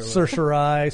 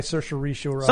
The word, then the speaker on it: certiorari: Certiorari. – Certiorari.